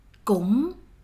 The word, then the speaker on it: cũng: Close your mouth when you pronounce this word. Gong, gong, gong.